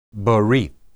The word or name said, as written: berith